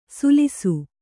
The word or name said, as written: ಸುಲಿಸು